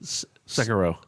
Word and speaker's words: Sekiro: Sekiro. – Sekiro? – Yes.